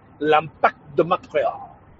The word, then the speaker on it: L'Impact de Montréal: It is the Montreal Impact, or as it's pronounced in French, L'Impact de Montréal.